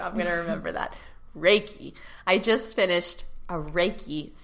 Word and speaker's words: Reiki: Ray, like Ray. Reiki. So if you've been saying it wrong, guys, it's not Reiki.